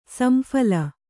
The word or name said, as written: ಸಂಫಲ